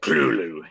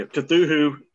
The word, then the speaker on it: Cthulhu: Yeah, Cthulhu is not the correct way to say it. Clulu. – It's not the preferred nomenclature. – Not the creator's preference.